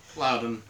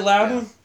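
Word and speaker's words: Loudoun: Loudon. Somebody needs to pronounce that for me. – Loudon. – Is it Loudon? Yeah. All right, thank God.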